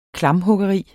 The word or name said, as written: klamphuggeri